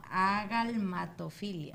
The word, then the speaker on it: agalmatofilia: Sí. Nalgamatofilia. – No, agalmatofilia.